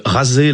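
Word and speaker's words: Razé (غزة): de Razé, la prononciation arabe de Gaza.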